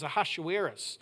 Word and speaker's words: Ahasuerus: And for Esther, we sort of track through the book as to the King Xerxes, known in some versions as Ahasuerus, which I enjoy sounds in the Hebrew pronunciation, something like headache.